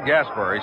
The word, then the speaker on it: Gaspar: Rod Gasper. He spells his name G-A-S-P-A-R. Pronounces it as it were spelled E-R.